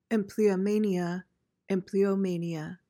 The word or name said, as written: empleomania